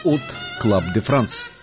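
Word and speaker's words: Hot Club de France: В 1932 году Юг основал «От Клаб де Франс». Первое «H» французы не произносят, отсюда и фонетическое «От Клаб де Франс».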